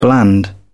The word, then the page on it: bland: British English